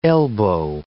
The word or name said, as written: elbow